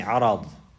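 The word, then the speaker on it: عَرَض: Now if you pronounce it with the fatha, On the ra you say arad, arad, arad.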